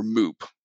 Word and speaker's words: MEWP: or MOOP. That's how at least I pronounce it, M-E-W-P.